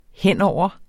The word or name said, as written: henover